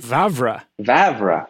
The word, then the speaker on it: Vavra: Vavra.